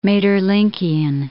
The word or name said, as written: Maeterlinckian